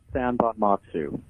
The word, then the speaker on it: Sanbonmatsu: It's pronounced San Bonmatsu, which is actually how it's spelled, S-A-N-B-O-N-M-A-T-S-U.